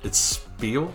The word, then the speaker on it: Spiel: Spiel is a word, right? – Spiel, yeah. – It's Spiel? – It's spelled the same as the German word. – Yeah. – But Spiel.